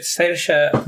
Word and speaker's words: Saoirse: No, así no se pronuncia realmente. – Saoirse Ronan. – Saoirse Ronan, ex, sí, es así.